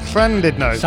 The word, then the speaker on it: Llandudno: Hello and good evening from Llandudno. Did I say that right? – Llandudno.